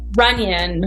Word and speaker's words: Runion: How do you say your last name, Natalie? Runion.